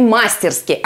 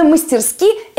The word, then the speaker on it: мастерски: Очень обидно, что сам ведущий обходится с ударениями мастерски, а не мастерски. Потому что именно мастерски – это литературная норма.